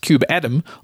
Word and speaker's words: kubeadm: KubeADM, sometimes pronounced KubeAdam, also has a new logo, with a ship's wheel inside an atom.